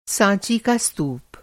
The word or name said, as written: स्तूप